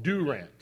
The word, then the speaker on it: Durant: Or actually, I was told it's called Durant, Texas, not Durant.